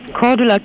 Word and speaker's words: Cordula: Mein Name ist Cordula Kine.